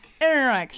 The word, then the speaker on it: Ehrenreich: So Alden Ehrenreich, if I'm saying that right, that's the actor who's going to play Han Solo. Yeah. So Ehrenreich.